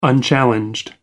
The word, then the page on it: unchallenged: American English